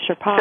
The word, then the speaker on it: Sherpa: Lawrence Murray, the author of Four Pillars of Success, the Shapar, Shapar, I'm saying that wrong, aren't I? The Sherpa, Sherpa, Sherpa.